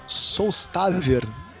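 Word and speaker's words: Sólstafir: Ah, uma banda que chama Solstavir. Na verdade, se, se escreve Solstafir.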